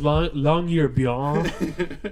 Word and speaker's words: Longyearbyen: Yeah, it's actually pronounced Longyearbyen.